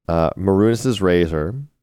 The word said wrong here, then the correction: Mehrunes' Razor: Mehrunes' Razor. – Is that how you say it? – Mehrunes.